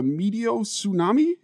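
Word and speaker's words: meteotsunami: Is it a medio tsunami? Is that is that yeah? You right? pronounced that right.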